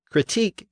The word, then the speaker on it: critique: Critique came in over a century later, and it retains its original stress on the final syllable.